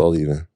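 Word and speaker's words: A11even: So it has the A, then the number 11, but it's pronounced All Even.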